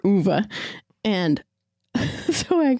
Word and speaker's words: Ove: Like you guys haven't heard it yet, but we'll talk about a man called Uwe. Apparently, that's how you say it.